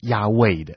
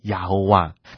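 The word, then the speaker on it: 雅威: 因为当学者去还原整个当时的母音的时候，根据查考，其实呢是应该读成亚卫的。所以在旧约当中，其实神的名字是亚卫，而不是把这个阿多奈的母音呢硬生生的塞到原本这个亚卫的子音的部分，念成亚侯哇。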